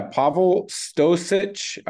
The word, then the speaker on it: Pavle Stosic: But yeah, Pavle Stosic, I believe is how you pronounce the last name.